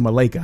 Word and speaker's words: Malaika: Her name is Malaika. We pronounce it Malaika. I don't know if it's Malaika Arora, A-R-O-R-A.